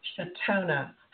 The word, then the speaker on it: Chautona: Now, wait, did I pronounce it right? Because I see it's Chautona. – Yes, it's Chautona, yeah. – Chautona Havig.